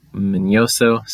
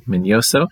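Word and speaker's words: Miñoso: So I was most excited about Buck O'Neill and Minnie Minoso as I'm Minoso, sorry, saying his name wrong, Minoso,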